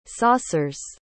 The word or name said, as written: saucer's